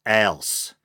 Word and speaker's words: Alse: And they actually had A-L-S-E. And it turns out, at least according to this site, that it's supposed to be pronounced else. So soft S, the E is silent.